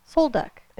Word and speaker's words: Sol Duc: No, actually, so there's one near here called Soul Duck. And it actually, Soul Duck? – Soul Duck. – Please tell me it's spelled S-O-U-L-D-U-C-K. – S-O-L-D-U-C.